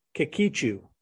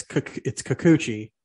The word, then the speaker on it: Kikuchi: I'm going Yusei Kikuchi. – You're close. It's Kikuchi.